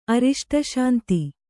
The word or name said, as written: ಅರಿಷ್ಟಶಾಂತಿ